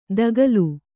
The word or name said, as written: ದಾಕಲು